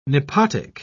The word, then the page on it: nepotic: British English